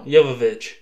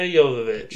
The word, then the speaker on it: Jovovich: Can you pronounce her name for real? – No. – Jovovich. – Oh, is that it? – Yes. Mia Jovovich. – Jokovic.